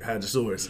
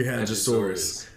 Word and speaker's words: hadrosaurus: That's how you say it, hadrosaurus. – You got that quick. – Say it with me. 1, 2, 3, hadrosaurus.